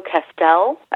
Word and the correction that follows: Celeste: I think that's how you say it. I'm so terrible at Spanish. – How is it spelled? C-A-S-T-E-L? – C-E-L-E-S-T-E. – Oh. Celeste. Yeah.